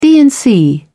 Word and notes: d and c: British English

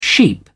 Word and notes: sheep: British English

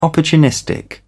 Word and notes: opportunistic: British English

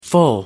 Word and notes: full: American English

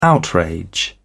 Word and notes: outrage: British English

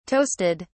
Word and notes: toasted: British English

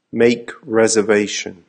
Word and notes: make reservation (for a table): American English

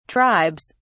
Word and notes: tribes: British English

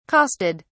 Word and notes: costed: British English

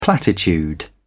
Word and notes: platitude: British English

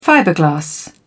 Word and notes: fiberglass: British English

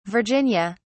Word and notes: virginia: British English

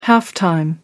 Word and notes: half time: American English

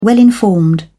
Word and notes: well-informed: British English

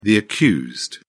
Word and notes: accused: British English

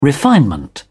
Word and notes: refinement: British English

